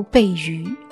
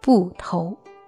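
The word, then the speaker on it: 馎饦: 这两个字比较难读，乍一看以为是形声字，读背鱼，其实正确的读音是布头。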